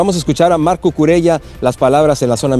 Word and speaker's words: Cucurella: Sí, no es Cucurella, es Cucurella, él mismo lo pronuncia de esta forma, y dice Cucurella que él prefiere jugar feo, que no le importa ser un fútbol horrible, pero seguir avanzando.